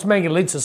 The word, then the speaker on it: Mangalitsa: Well, it's Mangalitsa. So Mangalitsa is a special type breed of pig that's like Hungarian. – Mangalitsa, okay, that's how you pronounce it.